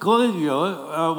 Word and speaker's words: Caligula: And then was it before Nero that we had Caligula? Is that how you say it? – Caligula was about 20 years before.